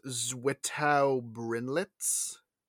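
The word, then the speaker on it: Zwittau-Brinnlitz: I think it's pronounced Zwittau-Brinlitz.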